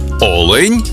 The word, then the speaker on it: олень: в однині і в множині наголос падає на перший склад – олень та олені.